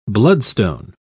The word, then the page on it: bloodstone: British English